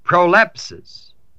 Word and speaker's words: prolepsis: And this is the figure in here called prolepsis. And it's spelled P-R-O-L-E-P-S-I-S. It also has another Greek word that they use, apantesis, A-P-A-N-T-E-S-I-S.